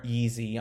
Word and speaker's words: Yeezy: Kanye West and Gap announced that they have signed a 10-year deal that will be a collaboration on a new collection called YZY, or Yeezy, I'm pretty sure is how they're planning on pronouncing it, that will be out in the first half of 2021.